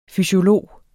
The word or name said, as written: fysiolog